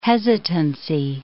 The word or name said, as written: hesitancy